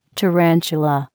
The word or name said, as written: tarantula